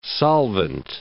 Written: solvent

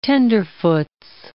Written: tenderfoots

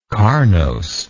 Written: carnose

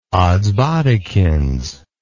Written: odsbodikins

